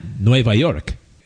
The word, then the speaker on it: Nueva York: Nope, not Nueva York, as New York is spelled and pronounced in Spanish.